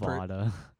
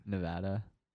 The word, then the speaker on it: Nevada: Nevada. Is that how you say that? – How do you say it? – Nevada.